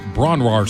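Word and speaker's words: Bron-Yr-Aur: I've seen it on pretty good authority that it's pronounced Bronwar Stomp, which is what I'm going with.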